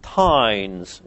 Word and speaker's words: teind: These tithes you'll find referred to in the literature as tines. It's a Scottish word. It's a Scots word. Tined is spelled T-E-I-N-D, and the tined is the agricultural tithe.